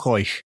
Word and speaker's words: Oich: And slap bang in the middle of that fault line is Loch Oich. – Oich. – Oich. – Oich. – Oich. Or Loch Oich, if you want to pronounce it Englishly and wrong.